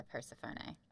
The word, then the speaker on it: Persephone: Next week, it's actually a Persephone. Just saying. – Sure. – It is. That's the proper pronunciation.